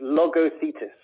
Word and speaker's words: Logothetis: you kind of butchered it, but don't worry about it. It's Logothetis.